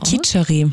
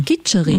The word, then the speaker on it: Khichdi: Khichdi. – Khichdi.